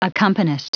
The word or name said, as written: accompanist